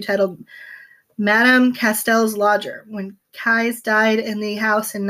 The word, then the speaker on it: Keyes: Keyes, like Cobra Kai.